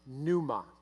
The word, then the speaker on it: pneuma: Now, this word for spirit in the New Testament, the Greek word is pneuma. Can you say that with me? Pneuma. If you're reading it in your notes, there's a P. You don't pronounce the P, it's silent. It's not pneuma, it's pneuma.